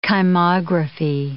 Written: kymography

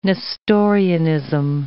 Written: Nestorianism

